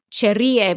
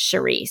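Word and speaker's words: Cherríe: I had said Cherie because I speak Spanish and there's an accent over the I, and it's not a name mm-hmm. I had seen before. So I was pronouncing it like what it would be in Spanish, but apparently she pronounces it Shuri.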